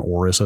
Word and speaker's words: Aorus: Um, the ongoing problem I had with the Gigabyte motherboard, which was an X670E Aorus, Aorus, I think people have landed on Aorus as the pronunciation. – A O R U S, I believe yes, is the spelling.